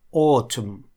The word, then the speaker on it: autumn: Autumn. Autumn. Здесь слово немножко не по правилу читается. Здесь, во-первых, первые две буквы AU читаются звуком O, а вторая U вообще выпускается в этом слове. Autumn.